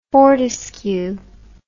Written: fortescue

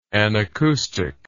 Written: anacoustic

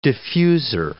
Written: diffuser

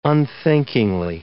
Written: unthinkingly

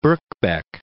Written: Birkbeck

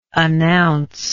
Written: announceable

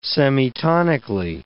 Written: semitonically

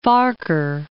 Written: Farquhar

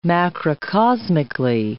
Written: macrocosmically

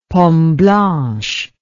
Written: pomme blanche

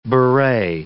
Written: beret

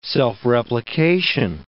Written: self-replication

